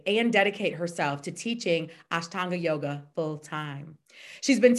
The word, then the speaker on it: Ashtanga: Helena Vesic is an amazing, amazing, amazing, amazing yoga teacher, Ashtanga.